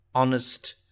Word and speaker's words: honest: Here, though, honest is the H is silent and we're pronouncing O-N-E-S-T. Honest.